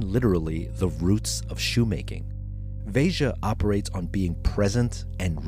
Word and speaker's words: Veja: By the way, you pronounce the J. It's not an H. It's not Veja. It's Veja.